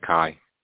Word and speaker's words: Khai: My name is Kai. – How do you spell that? – K-H-A-I.